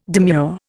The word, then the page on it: demure: Indian